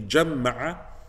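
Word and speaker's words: جمّع: Ibn Ashur, rahimahullah, said, those scholars or those recitations that say jamma'a, it is also because then it rhymes with addada, right? الذي جمع مالا وعدد right? You say جمع عدد, right? So both of them have a تشديد, and so he said even from a linguistic point of view, it then makes it equal in that sense as well.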